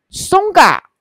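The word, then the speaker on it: Tsonga: 好，这里讲到我们的松嘎松家那个 T 不发音，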